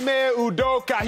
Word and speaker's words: Ime Udoka: Ime Udoka. He's my last Nigerian that I can really cling to. Bam Adebayo as well in the playoffs, too. – Uh-huh. – Ime Udoka, I'm riding with him from now on.